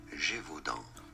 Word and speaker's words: Gévaudan: In French, it is said as Gévaudan.